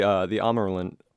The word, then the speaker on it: Ammerlin: See, I was going to go with like the long A. And a. So the Ammerlin, did I do that right? – Yeah, you got it right.